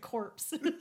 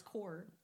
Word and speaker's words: corps: Yeah, but you're calling it Corps. – Is that not how you agree? – Almost as good as calling it corpse. – The demon corpse? Wait, is it pronounced demon? Isn't it C-O-R-P-S? – Yeah, but it's core. – Oh, you don't pronounce the S? – No.